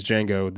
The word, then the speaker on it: Django: Fox's character drinks and talks with a man, and that man next to him, tells him, "My name is Django, the D is silent."